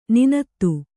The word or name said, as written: ನುಣ್ಣಿತು